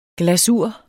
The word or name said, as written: GLASUR